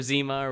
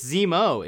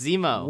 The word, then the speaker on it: Zemo: Yeah, it's Zemo. It's Zemo. – What? – It's Zemo. It's spelled Z-E-M-O.